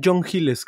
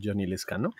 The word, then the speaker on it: Johnny Lescano: Em, el amigo John Hill como escuché que le decían en RTV, que en realidad es Johnny Lescano, este, porque la H no se pronuncia como J a menos que estemos hablando en inglés.